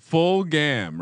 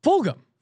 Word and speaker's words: Fulgham: I mean, we may have to talk to human resources if you're referring to my man Travis Fulgham, which no one can pronounce this man's name right. I've listened to every single podcast. – Fulgham, right? – Fulgham. – Fulgham. – Yes.